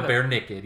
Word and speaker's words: BearNicked: But we just spell it like the animal, BearNicked. Yeah, just throw it all together.